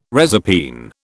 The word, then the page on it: reserpine: British English